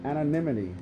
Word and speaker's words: anonymity: Because they're talking about here, now he swings around the city cloaked in his animantium. And did I say it right? Check if I said it right. – Oh, anonymity. – Oh, sorry, my bad. – Anonymity, guys.